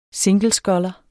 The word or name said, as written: singlesculler